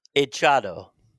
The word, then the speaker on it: Hechizado: Really, H-E-C-H-I-Z-A-D-O. – Hechado. – Hechado? – You don't pronounce the H.